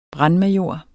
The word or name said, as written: brandmajor